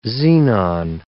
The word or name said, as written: xenon